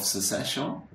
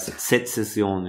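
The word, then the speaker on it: Sezession: Hello, and I'm here in Vienna with Martin Lichtmess of Secession. Is that, am I pronouncing it correctly? – No, it's Secession, you know.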